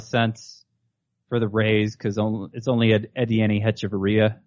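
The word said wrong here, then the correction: Adeiny Hechavarria: Adani Echevarria.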